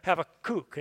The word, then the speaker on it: Habakkuk: Like last week, we talked about Habakkuk and how just understanding how to say his name was helpful to me, because it ends with a Habakkuk. And do you remember, if you were here, that kook part of it helps me remember what the book of Habakkuk is about, that God calls us all to be kooks for him.